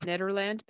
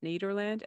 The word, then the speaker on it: Niederland: Yeah, so it's Dusty Bottoms, Ned Niederland. I feel it should be pronounced Nederland, but it's Niederland, and Lucky Day, which is obviously Steve Martin Short, and Chevy Chase, mm-hmm.